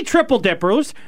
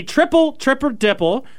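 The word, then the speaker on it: triple dipper: He chose to bring in three triple dippers, the triple dipple. I didn't pronounce that right at all.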